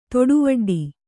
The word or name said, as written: ತೊಡುವಡ್ಡಿ